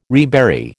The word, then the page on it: rebury: American English